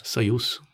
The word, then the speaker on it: Союз: Det uttalas väl Soyuz? Soyuz. Союз mm.